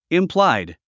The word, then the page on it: implied: impláid; インプライド